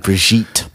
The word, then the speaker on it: Brigitte: I'm gonna tell you, Brigitte Nielsen, Brigitte, I think is how you say her name. – Yeah, Brigitte.